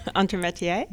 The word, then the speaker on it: entremetier: Entremetier.